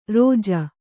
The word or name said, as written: ರೋಜ